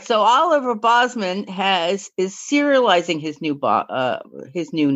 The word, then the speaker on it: Olivier: Is it Olivier or Oliver? We've had this discussion. – It's Oliver. It looks like it's spelled Olivier, but it goes by Oliver.